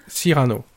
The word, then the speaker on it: Cyrano: Do you say Cyrano? Is it the emphasis on the first syllable? – Cyrano.